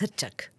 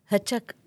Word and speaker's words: Hrčak: Yeah, so that's the repository, but I also read something completely different that you've been active in, and it's, pardon my pronunciation, but it's HRCAK, Hrač or something like that. – Yes. – Yeah, how do you pronounce that first? – Hrčak. Hrčak, it's hamster in English.